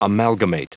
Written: amalgamate

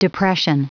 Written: depression

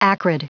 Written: acrid

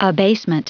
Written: abasement

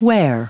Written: ware

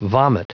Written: vomit

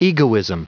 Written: egoism

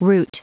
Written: route

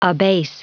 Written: abase

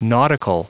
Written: nautical

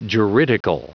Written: juridical

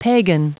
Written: pagan

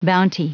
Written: bounty

